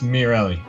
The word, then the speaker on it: Miorelli: But his real name is Ryan, is it Miorelli? Is that how you say it? – Closer than most. We've been friends for 10 years, and I am here to tell you that it is Miorelli.